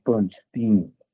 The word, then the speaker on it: Bernstein: Leonard Bernstein, I am told, would get ticked to the max if you pronounce his name Bernstein.